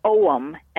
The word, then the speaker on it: Ogham: Now they had their own type of runes, and it was called the Ogham, and the Ogham or Oum, as they sometimes pronounce it,